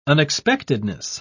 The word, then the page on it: unexpectedness: British English